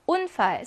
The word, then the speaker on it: Unfall: Unfall, 사고란 뜻이고요. 강세는 당연히 이름절에 있습니다. 강세 모음 뒤에 그러면 자음의 개수 일단 세어볼까요? 몇 개예요? 두 개가 나오죠. 이럴 경우는 짧은 우 발음이죠.